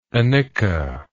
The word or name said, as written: anicca